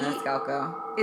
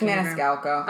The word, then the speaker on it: Maniscalco: Maniscalco.